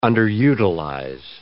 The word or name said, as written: underutilize